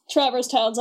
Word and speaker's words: Traverse Town: But since Traverse Town's at the tutorial world.